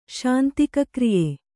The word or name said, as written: ಶಾಂತಿಕಕ್ರಿಯೆ